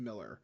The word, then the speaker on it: Millar: I can't remember which one, but I'm pretty sure it's pronounced Miller, just spelled Millar.